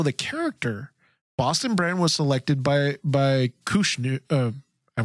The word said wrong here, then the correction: Krishna: want to say, did I say that right? Kushnu? – Krishna, I think. Krishna.